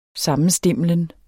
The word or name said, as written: sammenstimlen